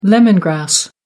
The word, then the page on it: lemon grass: American English